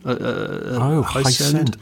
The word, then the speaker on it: Hiscend: Hisend, not as in like It's H-I... Oh, hang on. I've just gone off the... The Dynamo, it's spelt H-I-S-C-E-N-D. As in ascending? – High-send.